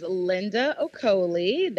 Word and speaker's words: Linda Okoli: So my name is Linda Okoli. That's L-I-N-D-A O-K-O-L-I.